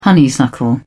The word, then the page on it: honeysuckle: British English